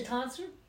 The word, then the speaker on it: tonsure: How was that pronounced? – The tonsure? – Thank you. – A what? – The tonsor.